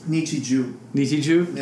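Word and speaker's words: Nichijou: I haven't seen all of it, but I really like Nichiji. Is that how you say it? – Nichiju. Yeah.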